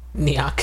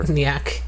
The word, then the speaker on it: Nyack: Nyack. – Whatever. – Nyack.